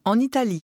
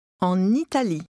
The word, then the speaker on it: en Italie: On va en Italie. Italie. En Italie. En Italie. – Note the liaison.